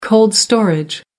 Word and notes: cold storage: American English

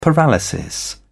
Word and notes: paralysis: British English

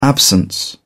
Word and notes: absence: British English

